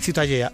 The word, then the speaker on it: Ciutadella: Ciutadella.